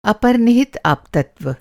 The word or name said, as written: अप